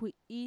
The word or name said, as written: kui'i